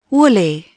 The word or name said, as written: woolly